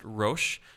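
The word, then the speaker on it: rosh (ראש): There's a word in Hebrew pronounced rosh, and it means the head, the top.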